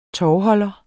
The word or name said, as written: tovholder